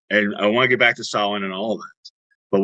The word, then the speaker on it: Samhain: And it's spelled S-A-M-H-A-I-N, just right, to confuse us Americans. And that's not really why. But in the old Gaelic language, it was said, it was pronounced Samhain.